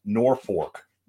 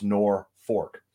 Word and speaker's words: Norfolk: But this one is pronounced Norfolk. Even though there's an L in it, there's no R. Norfolk is how it's spelled. It's pronounced Norfolk. The reason being is because it's short for the North Fork of a river, but they spelled it N-O-R-F-O-L-K for some god-awful reason. But it's Norfolk.